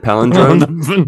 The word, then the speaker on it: palindrome: Palindrome? The palindrome? – Palindrome, I believe. – Episode? The palindrome. Ooh, you got to put the emphasis on the different syllable. I gotcha. Good to know.